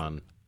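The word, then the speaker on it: Kuban: So it's what I'm looking at, a Wehrmacht motorcycle, a wedding ring, and a, I don't know if it's Cuban or Cuban, it's a K-U-B-A-N shield. That's a combat badge of the Wehrmacht. – Cuban. – Yeah. In the German, pronunciation.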